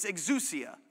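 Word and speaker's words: exousia (ἐξουσία): The one that he uses, and I looked this up so I know how to say it, it's exousia.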